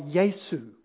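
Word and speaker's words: Yesu (Ἰησοῦ): It doesn't say Jesus. It says something like Yesu, Yesu. And those little squiggles that you put, that's to help with the pronounce it properly.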